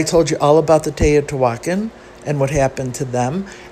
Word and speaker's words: Teotihuacan: If you saw the way this word is spelled, you would never come up with Teotihuacan,